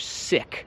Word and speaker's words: sick: I ja wiem, że tam jest i, ale to i się wymawia jak litera Y, albo co to jest Y, w słowu sif. To się mówi sick. I am sick, nie I am sick.